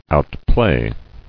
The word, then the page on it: Outplay: out·play